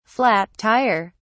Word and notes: flat tire: British English

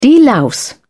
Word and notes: delouse: British English

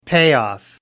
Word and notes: payoff: American English

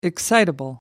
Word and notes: excitable: American English

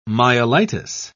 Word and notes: myelitis: British English

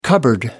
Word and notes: cupboard: American English

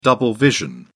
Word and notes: double vision: British English

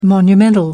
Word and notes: monumental: American English

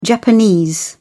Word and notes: Japanese: British English

